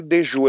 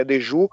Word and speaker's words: Dejours: É, o autor é o Christophe Dejoux. eu acredito que a pronúncia seja essa em francês. É, se escreve D-E J-O-U-R-S. Christopher é, Deju, A Loucura do Trabalho.